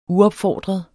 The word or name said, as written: UOPFORDREDE